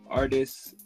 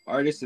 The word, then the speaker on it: Artis: So all my social media is at Michael Artis, M-A. Artis is spelled A-R-T-I-S.